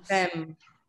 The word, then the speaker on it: femme: Femme. – Très bien. So actually, this one, comment est-ce qu'on dit? – Femme. – Ok. So another confusing thing. – Femme. – But it's pronounced femme.